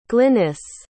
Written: glynnis

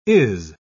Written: is

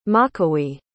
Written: makawee

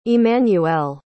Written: emmanuelle

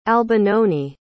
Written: albinoni

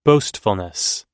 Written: boastfulness

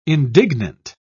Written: indignant

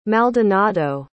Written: maldonado

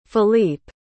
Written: Phillipe

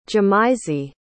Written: jahmisey

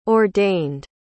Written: ordained

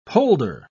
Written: polder

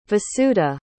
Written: vasudha

